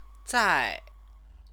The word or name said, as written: zai